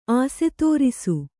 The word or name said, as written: ಆಸ್ತರಿಸು